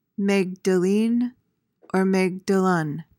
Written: Magdalene

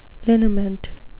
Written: liniment

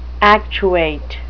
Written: actuate

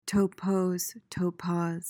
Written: topos

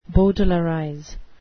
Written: bowdlerize

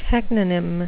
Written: teknonym

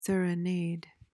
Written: serenade

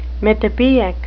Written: mythopoeic

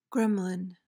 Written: gremlin

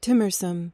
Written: timorsome

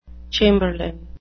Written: chamberlain

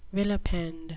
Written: vilipend

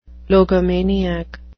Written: logomaniac

